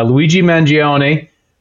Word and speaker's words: Luigi Mangione: We're going to be talking about Luigi Mangione.